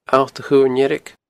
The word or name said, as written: Allt a Chuirn Dheirg